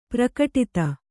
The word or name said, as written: ಪ್ರಕಟಿತ